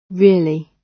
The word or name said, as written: really